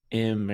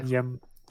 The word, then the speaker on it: YM: You said like M or something like that. It's the name is YM, just the letters YM.